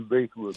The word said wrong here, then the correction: Bythewood: Bythewood, yeah, yeah.